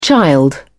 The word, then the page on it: child: British English